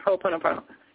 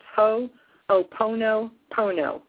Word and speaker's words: Ho'oponopono: It's spelled H-O-O-P-O-N-O. It's exactly like it sounds. Ho'oponopono. All O's.